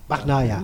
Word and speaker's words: Bagnaia: Maar de MotoGP is voor Banaya. – Bagnaia.